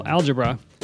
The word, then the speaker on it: algebra: And later, the emphasis was on the first syllable, algebra, but that's where the word comes from.